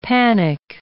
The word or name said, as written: panic